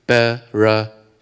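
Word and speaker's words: Bereshit (בראשית): Okay, so this Bereshit.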